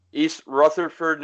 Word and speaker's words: East Rutherford: well, anyway, this is episode seven of Ruthless Retrospective and this is Raw from July 15th, 2002 in re in East Rutherford. Am I saying that right? – East Rutherford, New Jersey, which is now home to the Giants and Jets stadium.